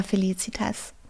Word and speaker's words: Felicitas: Nee, einfach Felicitas.